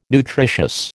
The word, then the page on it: nutritious: American English